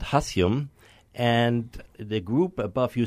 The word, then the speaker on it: hassium: A good example is hassium. Hassium is element number 108. – How do you spell it? – H-A-S-S-I-U-M. – Hassium.